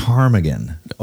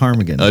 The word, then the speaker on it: ptarmigan: It's a ptarmigan. – A ptarmigan. Okay. – And it's spelled P-T Armigan. Ptarmigan. – Oh, for real? – Yeah, it's okay. It's spelled, the P is silent.